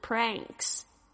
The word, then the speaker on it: pranks: 好，接下来另外一个呢，这个字 pranks， pranks 是简单的用一个字就可以代表恶搞， pranks， P-R-A-N-K-S。所以大家要特别注意一下这个发音，是这个后舌音呢，是 pranks 有一个舌头要后舌必须要抬高，发出这个很浓厚的这个鼻音 pranks。